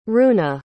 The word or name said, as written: runa